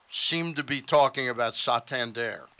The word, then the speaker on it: Santander: I've heard Santander. – Right. – Which is more emphasis on the sand at the beginning of it.